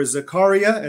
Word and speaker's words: Zakaria: In the midst of a lively televised exchange between journalist Fareed Zakaria, or Zakaria as he pronounces it, and Sam Harris on the topic of jihad, Zakaria declared, "The problem is you and Osama bin Laden agree.